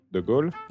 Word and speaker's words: de Gaulle: De Gaulle. – Oh, you are correct. Charles de Gaulle is correct.